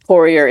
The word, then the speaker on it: Poirier: A-N-N-E. – Yep. – And then Poirier is P-O-I-R-I-E-R.